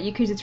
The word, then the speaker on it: Yakuza: So the first item we've got this week is something about Yakuza. What's that then? – You said it right. – I know how to say Yakuza.